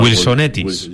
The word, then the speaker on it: Wilsonetis: como solo Wilson y Wilson, siempre lo digo mal. – Wilsonetis. – Yo siempre digo Wilsonetis. – Wilson y los Wilsonetis.